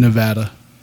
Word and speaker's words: Nevada: It's actually Nevada. they pronounce it that way if you live in that state.